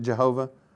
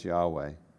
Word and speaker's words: Yahweh: But this is his personal covenant name, and you'll see it every time you see it, at least in English, you see L-O-R-D in caps. It's using this name. It's probably not right to pronounce it Jehovah. Right? That was a combination of the consonants and the, you know, then combining that with Adonai. Probably more right to pronounce it as Yahweh.